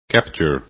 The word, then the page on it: capture: english